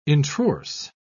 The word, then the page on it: introrse: British English